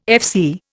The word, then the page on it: FC: Indian